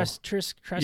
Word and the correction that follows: Teskey: Yeah, Teskey. – Teskey. I was close.